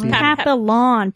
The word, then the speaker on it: Papillon: P-A-P-P-I-O-N. – Is French. – Or P-A-P-I-P-I-O-N. – Is that French for butter? – Oh, it's Papillon. P-A-P-I-L-L-O-N. Oh, okay.